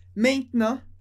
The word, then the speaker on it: maintenant: Maintenant. Maintenant, palavra muito importante, significa agora. Maintenant. Maintenant. Vou escrever aqui como a gente pronuncia ela. Maintenant. Tem várias letras, mas a gente pronuncia maintenant. Maintenant.